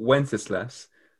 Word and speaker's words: Wenceslas: We've got a good King Wenceslas, which is absolutely how you say it.